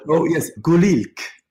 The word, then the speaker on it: Gulink: Gulink would be the pronunciation. Yeah. The Kurdish word for blossom.